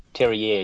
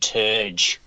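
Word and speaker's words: Terje: So months and months ago, you reviewed the album by, I believe you pronounce it, Todd Terrier. Terrier. He's a French producer. In Australia, we say Todd Terge.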